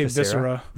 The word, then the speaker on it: viscera: Is that, am I saying that right? Visira. – I I, I say Viscera.